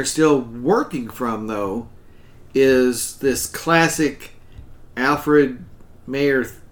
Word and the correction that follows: Mahan: I'm getting his name wrong. Alfred Thayer Mahan, right.